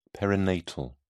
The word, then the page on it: perinatal: British English